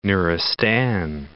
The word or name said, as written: Nuristan